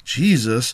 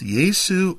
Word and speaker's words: Jesu: From our modern English rendition, Jesus Let's first go back one layer to 12th century early Middle English, and we have the form J-E-S-U, pronounced Yesu or Jesu.